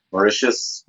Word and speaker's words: Mauritius: There's some fun names that Mauritius, number 11. Is that even how you say it? – Yeah, that's right.